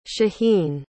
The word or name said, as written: shaheen